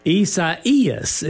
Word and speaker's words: Isaias: Back on WTOE now, as we're going to talk with you about Hurricane, and I'm going to try this, Andrew, Isaias is how you say it. – Yes, it's a Spanish version of Isaiah.